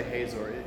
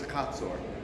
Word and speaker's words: Hatzor: People say Hazor. In Hebrew, it's Hatzor, um, is the way. So if it bugs you that I say Hatzor and you read and you've always heard it pronounced Hazor or Hazar or whatever, uh, just it's Hatzor.